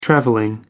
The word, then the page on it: traveling: American English